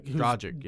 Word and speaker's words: Dragic: Dragic, yeah.